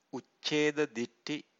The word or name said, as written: ucceda-ditthi